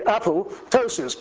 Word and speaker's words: apoptosis: Because PT is a phoneme in Greek. It's a silent P with the T pronounced, so this should be apoptosis, because ptosis in Greek means falling off, apo means from, so this is falling from.